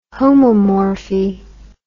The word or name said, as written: homomorphy